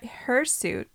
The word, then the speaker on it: hirsute: What hirsute, am I saying that right? H-I-R-S-U-T-E? – Sure.